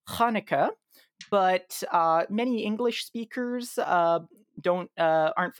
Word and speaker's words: Hanukkah: So the Hebrew pronunciation is Hanukkah. If I say that, I mean the same thing.